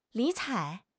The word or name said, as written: 理睬